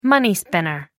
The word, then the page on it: money-spinner: American English